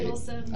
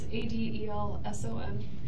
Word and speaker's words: Adelson: Adelson. – I know, I got to change that. – It's okay. It's Adelson. – Actually, it's spelled right. I said it wrong. Adelson.